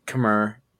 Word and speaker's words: Khmer: Khmer Empire. And I believe Khmer is the correct pronunciation of that.